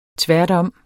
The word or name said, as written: tværtom